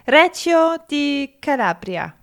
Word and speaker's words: Reggio di Calabria: Reggio di Calabria.